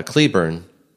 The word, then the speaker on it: Cleburne: Now, as a note of interest, I live in Texas and have driven through both Claiborne, Texas, pronounced by Cleburne by the locals, and Granbury, Texas, which are both just south of Fort Worth.